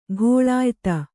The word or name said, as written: ಘೋಳಾಯ್ತ